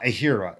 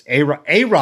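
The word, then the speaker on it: Ehire: It's not Ahira, even though it's spelled that way. It's Ara, I think, is what he told me. And I asked him how you spell it. I think it's Ara, basically.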